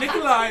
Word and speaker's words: Nikolaj: Nikolai and the J is silent.